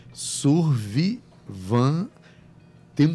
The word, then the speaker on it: Survivant: Survivan se escreve Survivan tem um T mudo no final. Então, Survivante. Se escreve assim: S-U-R-V-I-V-A-N-T.